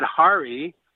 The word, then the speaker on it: Hari: Of course, when you add Hari, Ha, pronounced as in H-O and hot, Re, as in repeat, you get an added effect.